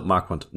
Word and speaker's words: Marquand: Ich, ich, bin mir immer nicht sicher, ob das Marquand oder Marquand ist. Marquand? Ich habe früher immer Marquand gesagt, bis ich dann mal irgendwann neulich eine Doku gesehen habe, wo sie es äh, weniger französisch ausgesprochen haben. Und es Marquand. ergibt ja auch keinen Sinn, dass der französisch ausgesprochen werden sollte. Ich glaube, der ist Waliser.